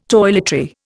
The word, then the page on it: toiletry: Indian